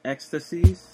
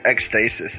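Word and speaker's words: Ekstasis: I've been listening to Julia Holter's new album, and I think you pronounce it Ecstasies. – Really? I just call it ecstasis is what I say. – Yeah, it might as well be, but, like, I think it's a play on ecstasy.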